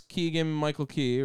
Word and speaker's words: Keegan-Michael Key: I saw that this week, and that's Keegan-Michael Key, right? – Yeah.